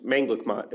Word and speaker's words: Manglicmot: It's Manglicmot is how you say it, but people say it a bajillion different ways.